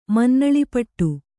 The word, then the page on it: ಮನ್ನಳಿಪಟ್ಟು: kannada